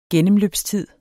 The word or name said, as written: gennemløbstid